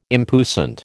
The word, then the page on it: impuissant: American English